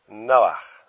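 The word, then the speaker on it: Noah (נח): They would have pronounced him Noah. Allow me to do what the Hebrew scholars do and put a dot beneath the H just to remind us of that pronunciation, the ch of J.S. Bach, or Loch Ness Monster.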